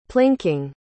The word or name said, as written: plinking